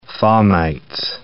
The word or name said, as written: fomites